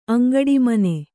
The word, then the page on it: ಅಂಗಡಿಮನೆ: Kannada